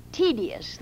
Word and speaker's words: tedious: Not tedious. The word, of course, is T-E-D-I-O-U-S, tedious.